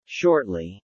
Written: shortly

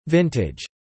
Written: vintage

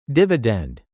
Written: dividend

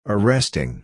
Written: arresting

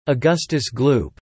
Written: Augustus Gloop

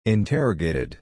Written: interrogated

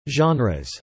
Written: genres

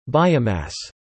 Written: biomass

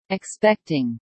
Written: expecting

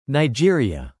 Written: Nigeria

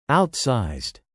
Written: outsized

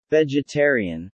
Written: vegetarian

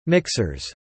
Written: mixers

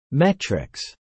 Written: metrics

